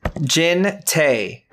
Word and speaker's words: Jintaek: J-I-N-T-A-E-K. – Say that again. Jin who? – Jin Tae. But it's spelt with like a silent K. J-I-N-T-A-E-K. – T-A-E-K.